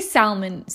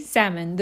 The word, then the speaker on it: salmon: It's just don't say salmon. Say salmon. The L is not The L is silent. – It's silent, guys.